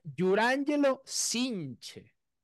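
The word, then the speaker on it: Jurrangelo Cijntje: Ok, volveremos a ver a un lanzador ambidiestro en la MLB, Yurángelo Cinche. Se pronuncia así, pero no se escribe así, muchachos, en verdad.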